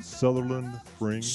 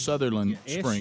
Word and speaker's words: Sutherland: How do you say the place again? – Sutherland Springs. – Sutherland Springs. – S-U-T-H-E-R-L-A-N-D.